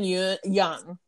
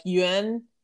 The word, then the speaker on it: Yeun: Steven Yeun. Yeah, it's a soft G because it's Y-E-U-N. And people have been pronouncing it like Yuen.